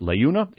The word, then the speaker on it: Leuna: That's spelled L-E-U-N-A. And by the way, in an upcoming segment that you're going to listen to, you will hear me mispronounce that. I pronounced it Leuna. It's pronounced Leuna.